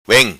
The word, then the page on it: Vem: patua